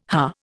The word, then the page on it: huh: Indian